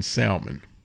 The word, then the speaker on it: salmon: Tell me why you don't pronounce the L in salmon. – Because you don't pronounce the P in psychology.